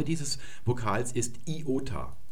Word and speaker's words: Iota: Denn dieses I wird immer I ausgesprochen und niemals J. Also wenn man zum Beispiel Ionien sagt, das ist eine falsche Aussprache. Auch der Name dieses Vokals ist Iota und nicht Jota.